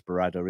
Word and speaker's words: Berrada: I keep getting his name wrong. It's Berrada, isn't it? That's my accent. I apologise.